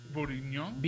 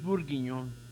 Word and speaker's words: bourguignon: Beef bourguignon. – Beef bourguignon.